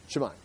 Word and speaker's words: Shimei: You got a pronunciation, Shimei? Okay, so. Okay, so say it for me. So, Shimei.